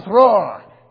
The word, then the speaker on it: Thror: Thorin, son of Thrain, son of Thror, king under the mountain, right? You have to say it like that. You have to trill the R's.